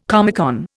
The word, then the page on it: Comecon: Indian